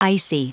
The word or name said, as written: icy